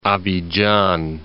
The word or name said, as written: Abidjan